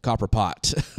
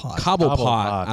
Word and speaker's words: Cobblepot: Oswald Copperpot. – Cobblepot.